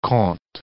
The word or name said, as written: Comte